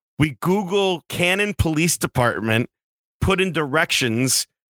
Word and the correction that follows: Canaan: You can say it wrong if you want, but it's Canaan. Canaan, sorry, Canaan Police Department, and it drives off this dirt road that it's all covered in snow.